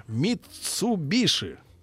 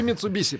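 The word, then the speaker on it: Mitsubishi: На первом подаренном мне в детстве еще иностранном карандаше было написано «Митсубиши». Или Митсубиси по-нашему. Да, и вот эта история с произношением. Пишется SH, а, а читается по-разному.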